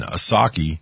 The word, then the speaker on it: Psaki: And Psaki, okay, again, remember the P is silent.